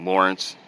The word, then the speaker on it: Lawrence: That was pretty weird, but, you know, my name's Lawrence, so they put L-A-U-R-E-N-C-E when my name is spelled L-A-W-R-E-N-C-E.